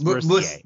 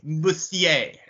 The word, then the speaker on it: Mercier: It's Mercier. Mercier. It's like Cormier.